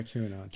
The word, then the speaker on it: QAnon: So why can't people who believe in QAnon, is that how you pronounce it? – Yeah, QAnon.